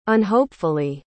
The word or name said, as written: unhopefully